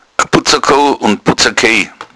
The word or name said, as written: butzakou